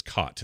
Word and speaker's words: Chott: Much to my surprise, I saw a name I recognize, Bill Chott, Cott is how you say it. The H is silent.